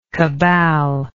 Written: caballer

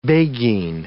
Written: beguine

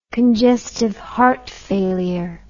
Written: congestive heart failure

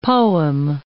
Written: poem

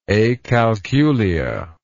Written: acalculia

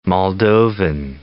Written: Moldovan

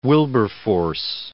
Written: Wilberforce

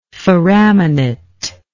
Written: foramination

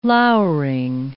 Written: lowering

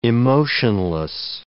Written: emotionless

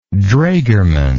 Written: draegerman

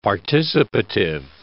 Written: participative

